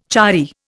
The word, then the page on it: chary: Indian